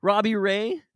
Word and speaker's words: Robbie Ray: And I think with the recent trade for Adam Frazier and signing of reigning Cy Young winner, I think, Robbie Ray.